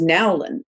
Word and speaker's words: Nowlin: It was Nowlin, which no one can spell it. It's N-O-W-L-I-N.